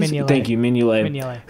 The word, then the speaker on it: Mignolet: because Bruges also has Simon Mignolet, I think is how you pronounce it. – Mignolet.